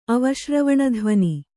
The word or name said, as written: ಅವಶ್ರವಣಧ್ವನಿ